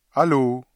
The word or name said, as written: allô